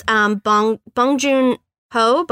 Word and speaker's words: Bong Joon-ho: But it's Bong Joon-ho.